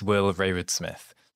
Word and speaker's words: Will Rayward-Smith: Yeah, my name is Will Rayward-Smith.